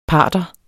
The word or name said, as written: parther